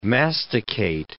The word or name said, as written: masticate